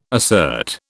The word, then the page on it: assert: British English